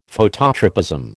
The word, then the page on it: Phototropism: American English